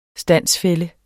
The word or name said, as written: stande